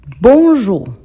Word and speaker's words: bonjour: Bueno, la ON es nasal, por eso suena bon, sale como de la nariz, bon. Jour, la OU es una U.